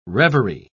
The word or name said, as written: reverie